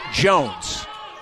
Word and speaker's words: Joens: Spelled J-O-E-N-S, but pronounced Jones.